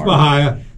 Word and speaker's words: Bahia: It's Bahia.